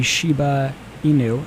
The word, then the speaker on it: Shiba Inu: You know, at the time, my Nintendogs dog was a Shiba Inu, if mm-hmm.